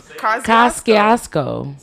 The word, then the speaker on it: Kosciuszko: Couscous Bridge. – Not the Couscous Bridge. – Yo, you have to be stuck. – Couscous. – Kosciuszko. – Say it one more time. – Kosciuszko.